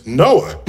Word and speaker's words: NOAA: And but if you pronounce the acronym, it's NOAA.